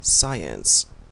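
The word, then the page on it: science: Canadian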